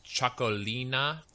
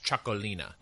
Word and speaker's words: Txakolina: It's Chocolina. It's spelled Txakolina. It's pronounced Chocolina.